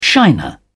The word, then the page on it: shiner: British English